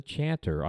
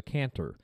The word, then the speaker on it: chanter: Besides the drone pipe, a bagpipe has a melody pipe that is called the chanter. I've also heard it pronounced the cantor.